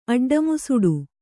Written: ಅಡ್ಡಮುಸುಡು

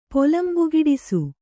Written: ಪೊಲಂಬುಗಿಡಿಸು

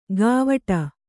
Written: ಗಾವಟ

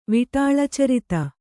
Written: ವಿಟಾಳಚರಿತ

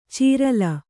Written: ಚೀರಲ

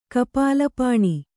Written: ಕಪಾಲಪಾಣಿ

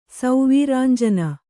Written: ಸೌವೀರಾಂಜನ

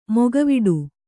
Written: ಮೊಕವಾಡ

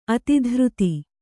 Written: ಅತಿಧೃತಿ

